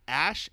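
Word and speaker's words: Æ: And the way you pronounce it is X, Ash, A-E is pronounced Ash, and A-12, Archangel.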